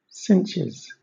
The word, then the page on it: cinches: British English